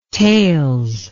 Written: tails

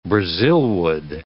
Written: brazilwood

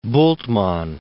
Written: Bultmann

